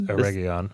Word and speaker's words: Eregion: The first time we see Eregion, how am I saying that right? Eregion. – It's Eregion.